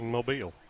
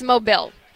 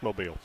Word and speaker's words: Mobile: How do you say it? – Mobile. – Mobile. He says Mobile. – No. It's Mobile.